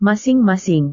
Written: masing-masing

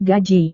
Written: gaji

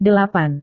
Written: delapan

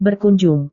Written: berkunjung

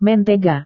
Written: mentega

